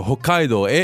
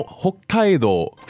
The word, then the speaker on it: 北海道: この北海道の発音, the pronunciation of Hokkaido, could you do it a few times for us? It's a little bit different. Because in English, we just say Hokkaido. – Oh. – But in Japanese, 日本語の発音はちょっと違いますね。 – Okay. 北海道。北海道。 – So, we have the double consonant on that K, the Hokkaido.